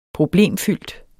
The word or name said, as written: PROBLEMFYLDT